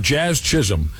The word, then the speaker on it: Jazz Chisholm: Jazz Chisholm.